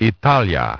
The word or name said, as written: Italia